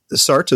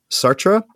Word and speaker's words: Sartre: So the French teacher at my school says, Sartre is fine, but technically it's Sartre, but yeah. Sartre with a French accent, which I cannot reproduce.